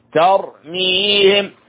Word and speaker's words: ترميهم: ترميهم التاء هنا مرققة ترميهم والراء هنا مفخم ترميهم هكذا تنطق ترميهم